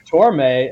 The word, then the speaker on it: Torme: I like Torme as in Marissa. – The Velvet Fog.